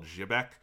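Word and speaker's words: Xebec: Sunrise Beyond was open around this time after they purchased and closed a company called Jibec, which it's X-E-B-E-C. Jibeku is how it's spelled in katakana.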